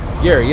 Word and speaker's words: Garey: Garey, yeah, yes, sir. – How do you spell it? – G-A-R-E-Y.